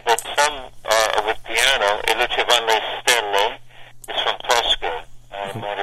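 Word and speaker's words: E lucevan le stelle: E Luce Van Le Stelle.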